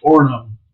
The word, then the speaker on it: Orono: I can always say the name wrong. Is that right? Orono. – Orono. – Orono. Orono. Yeah, you're like, don't say the second O because it's one of those weird Minnesota words.